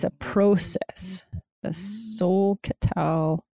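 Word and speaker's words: Solketal: How'd you spell that again? The Sol-Catal? – Is it S-O-K? – S-O-L. – S-O-L. Sol-Catal. Okay. – Yeah, and then K-E-T-A-L.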